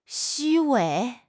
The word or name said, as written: 虚伪